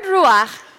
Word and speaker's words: ruach: The Hebrew word for wind is ruach. That's how you say it.